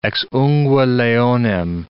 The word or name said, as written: ex ungue leonem